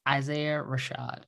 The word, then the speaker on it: Isaiah Rashad: Wait, no, I'm saying that wrong. Wait, I want to make sure I got his name right. I don't want to mess this up because I do have something yes, it is. Why is it coming up like that? It is Isaiah Rashad, who was a rapper with TDE.